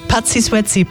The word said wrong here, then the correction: Patrick Swayze: Patrick Swayze, Don't Sie Ding Sie.